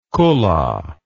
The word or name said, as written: kulla